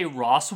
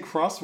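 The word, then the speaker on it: Xros: Do you want me to say Ross Wars then? – No, just say Cross Wars. That's what it's called.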